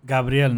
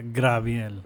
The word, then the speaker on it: Gabriel: I don't know if you know Juan Gabriel. Juan Gabriel is a very famous Mexican singer and composer. He had a song in the 80s called Gabriel, not Graviel.